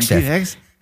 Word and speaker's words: Hegseth: Hegseth.